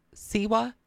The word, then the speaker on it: Siwa: Her statement was called out by Hillary Burton and JoJo Siwa.